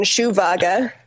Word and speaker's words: Schwager: It's Schwager. – Schwager. So I just want to say it with... Well, anyway, so well, how are you? – You can say it like German, Schuhwager.